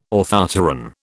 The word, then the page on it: orthopteran: British English